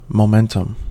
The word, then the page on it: momentum: American English